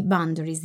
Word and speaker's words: boundaries: i boundaries in inglese, è corretta la pronuncia? Sì.